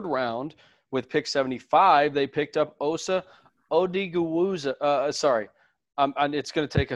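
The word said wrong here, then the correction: Odighizuwa: It's Odigazua, I believe. – Say again? Huh? – Odigazua, I think. – Yes, Odigazua, the defensive tackle out of UCLA.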